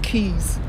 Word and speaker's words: Quay: West Indian Quay, but I know it's Keys. It's like, Keys is K-E-Y-S, Keys. I don't know, man. English for ya. Yeah, so it's like West India Keys, Heron Keys, and South Keys. But the way it's spelled is like Quay, Q-U-A-Y.